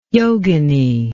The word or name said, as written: yogini